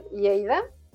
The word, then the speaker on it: Lleida: A ver, soy de México y llevo dos años y medio en España, específicamente en Cataluña y en Lleida.